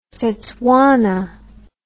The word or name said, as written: setswana